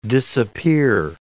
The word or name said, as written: disappear